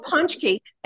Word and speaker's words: pączki: Now, that makes no sense, but what it is in Poland, it's a big celebration, and they have these donuts called pączki. It's not how it's spelled. It's got a Z and a, you know, Polish letters are crazy.